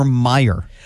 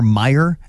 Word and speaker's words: Meijer: People around here cannot pronounce Kroger or Meijer correctly. – No, and it drives me nuts. – They put an S on it and there's no S there.